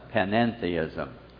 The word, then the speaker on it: panentheism: Notice it has an extra syllable there, E-N in the spelling, panentheism.